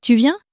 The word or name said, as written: tu viens